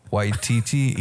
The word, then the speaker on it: Taika Waititi: Waititi.